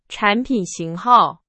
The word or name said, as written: 型号